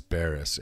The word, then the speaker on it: Barrus: Okay, now I've said your name 1,000 times, but what's the exact pronunciation of your last name? – Barrus. – And it's like, but it's spelled B-O-U? – B-A-R-R-U-S.